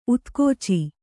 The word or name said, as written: ಊದುಗಿಚ್ಚು